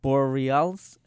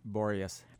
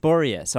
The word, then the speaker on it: Boreas: It's called the Boreas. How do you say your boat's name? – Boreas. – Boreas.